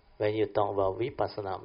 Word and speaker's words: vipassana: So vipassana is a Pali word. There are two syllables there. Vi is the three characteristics of nature. Passana is to insight into these three characteristics of nature. So you join these two words, vipassana.